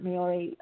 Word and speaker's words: Maori: And I remember with the Maori, is that how pronounced it, Maori elders and healers.